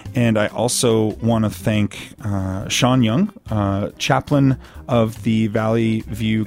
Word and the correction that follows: Jung: And do you say Young? – Jung. – Dang it. – Hard J. That's okay. My mistakes. I want to introduce Sean Jung, who is the chaplain of the Valley View Cancer Center, and also Gregory Cowan, co-owner of Defiance Rafting.